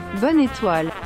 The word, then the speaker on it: Bonne étoile: Bonne étoile.